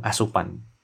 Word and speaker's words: Aseupan: Dan dia membahas tentang pendakian gunung asupan. Bacanya gimana? Asupan ya. Karena tulisannya A S E U P A N.